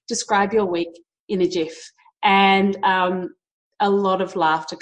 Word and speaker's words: GIF: Number five, GIF off, which is pronounced GIF, but I still say GIF with a hard G and it drives my husband insane.